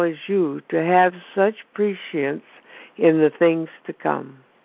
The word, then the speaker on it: prescience: There's an SH. It's so weird. Because I couldn't pronounce it either. It's prescience or prescience, whichever you choose.